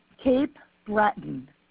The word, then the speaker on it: Cape Breton: Caperton? – Cape Breton.